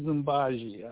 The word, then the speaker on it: Mbaji: My name is Mbaji. I spell that M-B-A-J-I. My friends call me Baj, B-A-J.